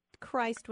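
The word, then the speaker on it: Crist: The you mentioned earlier, though, that Christ was originally pronounced Christ.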